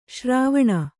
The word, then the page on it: ಶರವಣ: Kannada